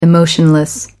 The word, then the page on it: emotionless: American English